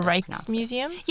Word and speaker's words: Rijksmuseum: Oh, the Rijksmuseum? – Yes.